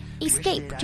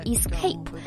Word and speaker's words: escape: Escape 这个单词呢，表示逃避、逃离。我们注意一下它的发音啊，不是 escape。 S 和 C 这么在一起呢，把 C 浊化了。